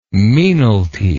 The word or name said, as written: mesnalty